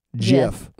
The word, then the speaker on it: GIF: The person who invented the GIF came out to end the debate finally and said it's pronounced like J-I-F. GIF.